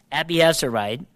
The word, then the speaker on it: Abiezrite: I actually looked this up to get the pronunciation correct, and it's Abiezrite. While his son Gideon threshed wheat in the wine press.